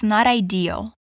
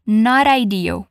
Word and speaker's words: not ideal: Um detalhezinho aqui de pronúncia é que esse T em not, ele pode soar com um som meio de R. Então, conectando com a palavra que vem depois, fica not ideal, not ideal.